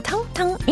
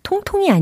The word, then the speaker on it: tongue: Rob Thomas의 Lonely No More 즐거운 영어 발음 공부 원 포인트 레슨 텅텅 English. 제가 좀 전에 어떻게 이 코너의 이름을 설명했죠? 즐거운 영어 발음 공부 원 포인트 레슨 그다음 뭐라고요? 텅텅 English. 그쵸. 자, 통통이 아니에요. 텅텅이었습니다. T-O-N-G-U-E 라는 철자죠.